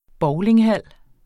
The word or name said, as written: bowlinghal